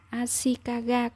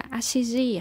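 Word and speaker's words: Ashikaga (足利): Ừ, tớ cũng dốt môn sử lắm, tớ còn chẳng biết chữ Hán có phải đọc là Ashikaga cơ, không phải đọc là Ashiji à?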